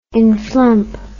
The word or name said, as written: inflump